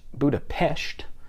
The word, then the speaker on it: Budapest: It is pronounced Budapest, so you'll hear that a lot.